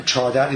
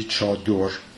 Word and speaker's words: Chador: Chadar is rhymed with Delavar, so this is another pronunciation of Chador. However, in the Middle Persian text, it is clearly Chador.